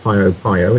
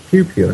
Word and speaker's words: Piopio: And Pio Pio, you know, when somebody said it's not Pio Pio, it's Pew Pew. And I thought, well, how do you get Pew Pew out of P-I-O P-I-O?